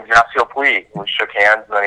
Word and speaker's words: Yasiel Puig: What was the first thing that Yasiel Yasiel Puig? Am I saying his name right? Uh, yes.